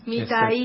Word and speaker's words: Mitaí: Exactamente, pero lo que tiene, porque mucha gente lo pronuncia Mitaí, porque la palabra está escrita sin acento en la I final. Ajá. Y es que para en guaraní las palabras agudas no se acentúan, se acentúan las graves.